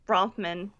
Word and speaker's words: Bronfman: Um, how do you pronounce it? Bronfam. – Bronfam. – Bronfman.